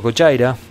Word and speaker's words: Gojira: Así que vamos a escuchar a Gojira, o Cochaira, como deberíamos pronunciarlo,